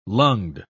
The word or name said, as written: lunged